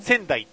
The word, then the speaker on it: 川内: でもほら、川内って書いてさ、仙台って読むじゃないですか。 – うん？ん？ – 山水、じゃ、縦三本の川に、うん。 – 内って書いて仙台って読むじゃないですか。